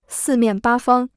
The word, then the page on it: 四面八方: sì miàn bā fāng